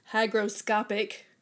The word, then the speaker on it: hygroscopic: what they're saying is um, that since salt is hyro, hold on, how do you say it? Hygroscopic